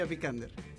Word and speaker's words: Vikander: Alicia Vikander.